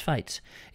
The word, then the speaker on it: Feez: Now, I knew Douglas, and used to repair his TVs and all sorts of things, and he always used to pronounce his name as Fates. It's spelled F-E-E-Z, but he used to call it Fates, so that's why it's the Douglas Fates Pavilion.